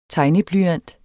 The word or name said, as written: tegneblyant